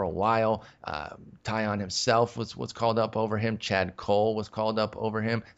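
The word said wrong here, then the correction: Kuhl: I mean, I'm German, and it looks like a German name, and I would say cool. – But... cool. Nope, you are right.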